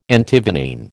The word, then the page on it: Antivenene: American English